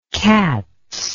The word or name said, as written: katz